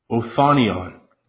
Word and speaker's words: othonion: John, however, is more descriptive, and he uses the word, Strong's 3608, Othonion. I could have butchered that, but it's O-T-H-O-N-I-O-N, so it's like off onion.